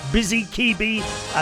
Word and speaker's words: Bzikebi: With Busy Kibi, I keep changing the name because you can't pronounce the damn thing. B-Z-I-K-E-B-I. Busy Kibi, and the song is called On Replay.